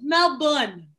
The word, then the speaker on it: Melbourne: He pronounced the R though. It's Melbourne.